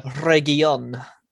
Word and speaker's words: Reguilón: Reguilón.